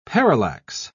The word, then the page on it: parallax: British English